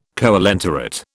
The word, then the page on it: coelenterate: British English